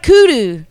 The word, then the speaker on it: kudu: Kudu, though. I tried kudu. – Okay. Yes. – Am I saying it right? – You are. Kudu.